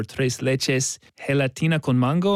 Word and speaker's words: tres leches gelatina con mango: She felt that she could do the recipe for tres leches gelatina con mango.